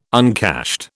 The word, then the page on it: uncashed: British English